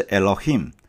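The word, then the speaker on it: Elohim， (אֱלֹהִים): 权柄与卓越，神发音是 Elohim，这是上帝的名字之一，强调的是神的超越性。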